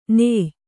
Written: ನೇಯ್

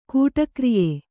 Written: ಕೂಟಕ್ರಿಯೆ

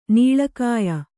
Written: ನೀಳಕಾಯ

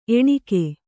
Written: ಎಣ್ಣಿಗ

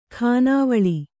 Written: ಖಾನಾವಳಿ

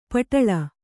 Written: ಪಟ್ಟಲು

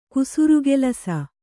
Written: ಕುಸುರುಕೆಲಸ

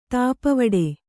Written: ತಾಪವಡೆ

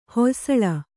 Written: ಹೊಯ್ಸಳ

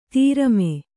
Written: ಧರುಮ

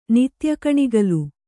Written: ನಿತ್ಯಕಣಿಗಲು

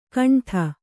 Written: ಘಂಟಾ

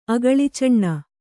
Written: ಅಗಳಿಚಣ್ಣ

